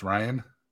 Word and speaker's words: Rian: So Rian Johnson has, you keep saying Rian, it's actually pronounced Ryan.